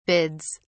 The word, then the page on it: bids: British English